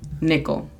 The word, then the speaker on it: nickel: Recordándoles que la pronunciación fonética de esta es N-I-K-L, nickel.